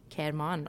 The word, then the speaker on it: Kerman: Und zwar bei einem Bombenanschlag in der Stadt Kelmon. Habe ich es richtig ausgesprochen? Kerman, oder? Ja.